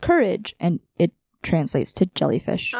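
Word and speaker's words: Kurage: And the doctor volunteered Courage, which I don't know if I'm pronouncing that right, but it's K-U-R-A-G-E. So it's Courage. What? You feel like what? – I feel like that was right.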